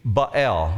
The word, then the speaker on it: Baal: Baal. When you see his name, B-A-A-L, in the Bible, you pronounce it that way, Baal.